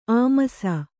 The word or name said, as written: ಆಮರ್ಷ